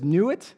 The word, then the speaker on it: Nut: Then there's Nuit, it's spelled N-U-T, Nuit, the sky goddess.